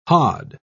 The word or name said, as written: hod